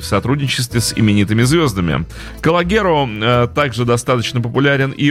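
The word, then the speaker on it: Calogero: Вот интересно мне, как правильно говорить «Калогеро» или Калогером? Ну, если по-французски, то «Калогеро». Ну, так произнесем этого исполнителя.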